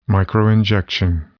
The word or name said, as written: microinjection